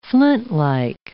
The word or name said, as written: flintlike